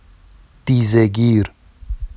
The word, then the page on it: dizeɟir: Vafsi